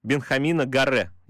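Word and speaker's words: Benjamín Garré: Э, игрока из системы Манчестер Сити, аргентинца, Бенхамина Гаре, если я правильно Все поставил верно, ударение.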